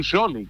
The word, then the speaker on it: Scioli: Daniel Scioli, claro, claro. SC es en italiano.